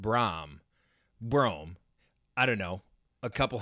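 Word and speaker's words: Brohm: It's pronounced Jeff Brom, like Tom. Thank you.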